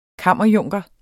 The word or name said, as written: kammerjunker